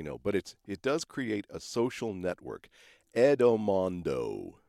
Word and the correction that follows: Endomondo: Endomondo. Sorry, I was saying it wrong. Endomondo. There.